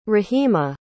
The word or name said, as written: rahima